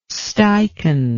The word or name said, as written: steichen